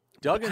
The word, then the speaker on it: Duggan: I think they pronounce it Duggan because it's two G's.